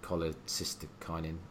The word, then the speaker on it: cholecystokinin: and cholecystokinin.